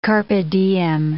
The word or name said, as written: carpe diem